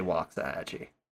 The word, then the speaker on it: Waxahachie: It's spelt Waxahachie, but yeah, in Texas, people say Waxahachie.